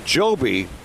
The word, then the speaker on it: Jobe: Today it's the Joby. It's written J-O-B-E, but pronounced Joby.